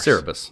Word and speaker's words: Cerberus: Cerebus. Cerberus. Cerberus. – That, that, maybe no, that. I, yeah, I said Cerberus, I Cerberus, and there's not an extra R in there.